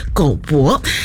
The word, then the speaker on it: GOBO: 所以呢再搜寻了一下呢，这一位呢独立乐团美秀集团的主唱，他的本名是叫做黄博汉，所以呢他的这个英文名还有这个社群的账号呢也就是直接的拼作 GOBO，所以呢我们就是把它念成了狗博。